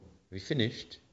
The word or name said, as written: have finished